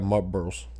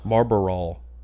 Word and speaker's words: Marlboro: I got Marlboros. – Marlboros. – Marlboral.